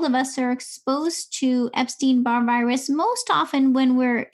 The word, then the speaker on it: Epstein-Barr: So I also found one of your posts, at least 90% of us have had EBV virus, and that is the Epstein-Barr virus.